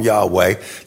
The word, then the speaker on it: Yahweh: And so Yahweh first creates, we'll call him Yahweh, that comes from a tetragrammaton in the Old Testament, YHWH. The most likely pronunciation is Yahweh, so I'm going to call him Yahweh.